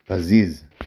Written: פזיז

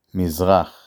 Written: מזרח

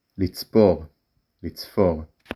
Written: לצפור

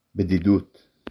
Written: בדידות

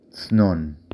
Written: צנון

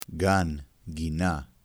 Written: gan

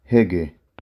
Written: הגה

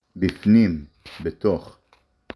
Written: בִּפְנִים

